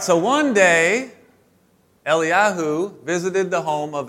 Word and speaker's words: Eliyahu: All right, but it's actually pronounced Eliyahu because they don't pronounce the J. So Elijah is not Elijah. It's actually Eliyahu.